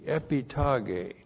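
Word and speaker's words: epitage (ἐπιταγή): Epitage, E-P-I-T-A-G, long E, epitage.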